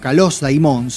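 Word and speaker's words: Kalos Daimon (Καλὸς δαίμων): Entonces, cuando uno dice Kalos Daimon, o Kalos Daimon sería la pronunciación correcta, en realidad lo que está diciendo es buen espíritu.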